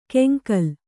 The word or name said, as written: ಕೆಂಗಲ್